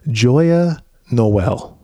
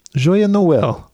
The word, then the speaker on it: Joyeux Noël: And I believe the French say Joyeux Noël. Joyeux Noël. That's how they say it.